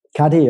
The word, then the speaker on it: Cuddy: da kam eben das Thema Power Pose und die Studie von Amy Cuddy, spricht man die Cuddy aus? – KD, ja.